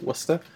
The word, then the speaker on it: Worcester: I think that's how the locals say it, Worcester.